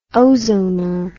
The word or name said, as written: ozoner